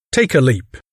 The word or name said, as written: take a leap